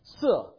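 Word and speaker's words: si (四): And the reason for that is the word in Chinese for the number four is si. The word for death in Chinese is si. And so it is almost identical pronunciation, just a different tone, different character.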